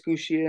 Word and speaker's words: Kushida: Tashida, is this his dude's name? I can't pronounce this fucker's Kushida.